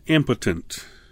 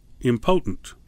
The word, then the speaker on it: impotent: What it means, impotent, not important. Please don't mispronounce it. M potent. Potent means with power. M, the prefix, means without. So without power. Impotent. Now, in the South, especially uh, if you talk to some of my friends in the South, they may pronounce impotent impotent. Okay? And that's fine. That's the way you want to pronounce it. But it does have a problem with it because it sounds like it's important instead of impotent.